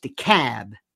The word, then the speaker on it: DeKalb: D E capital K A L B is pronounced DeKalb.